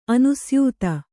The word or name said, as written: ಅನುಸ್ಯೂತ